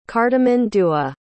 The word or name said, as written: cartimandua